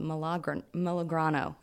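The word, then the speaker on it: Melograno: It is called Milagrano. That's the proper Italian pronunciation, right? Milagrano, which I think means pomegranate, but it is owned by a couple.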